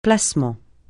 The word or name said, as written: placement